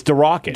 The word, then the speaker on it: Darracott: There's no way you said that correctly. – D-A-R-R-A-C-O-T-T. – Yeah, no way. – Derocket? – It's going to... – Derocket. – You know somebody's going to say... – It's Derocket.